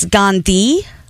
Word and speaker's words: Gandhi: You know, everybody actually pronounces my last name incorrectly because it's Gandhi, like may the force be with you, a the sound.